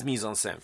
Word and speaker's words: mise-en-scène: So what is mise-en-scène? French for what is put into the scene. And I've got the pronunciation there. Mise-en-scène, right? You should just say it with me.